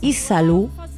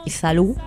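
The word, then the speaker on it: Yzalú: cantante brasileña que se llama Isalu, se escribe con Y-Z-A-L-U, con acento, Isalu,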